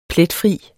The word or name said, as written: pletfri